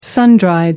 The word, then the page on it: sun dried: American English